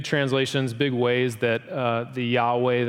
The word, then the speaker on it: Yahweh: Beginning in about the 4th century BC, the Jews considered this name of God, we're going to say Yahweh is the right pronunciation here.